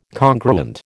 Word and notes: congruent: American English

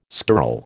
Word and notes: skirl: American English

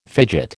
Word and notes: fidget: American English

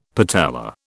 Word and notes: patella: British English

